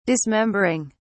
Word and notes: dismembering: British English

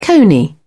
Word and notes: cony: British English

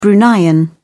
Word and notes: bruneian: British English

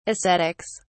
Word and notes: ascetics: British English

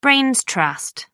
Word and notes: brains trust: American English

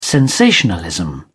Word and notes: sensationalism: British English

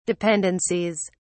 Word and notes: dependencies: British English

